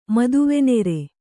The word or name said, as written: ಮದುವೆನೆರೆ